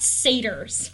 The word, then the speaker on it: satyrs: I'm really sorry to have to correct you, but I think the word is pronounced satyrs.